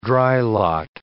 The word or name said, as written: drylot